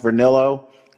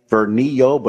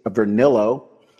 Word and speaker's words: Vernillo: So Heather Vernillo, and the last name is pronounced Vernillo. I thought it was perhaps a Latino last name, Vernillo, but it is Vernillo.